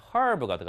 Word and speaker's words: herb: 자, 여기 있는 herbivore 할 때, 여기에 보니까, 아, herb가 들어가 있죠. herb 혹은 herb라고 읽는데요.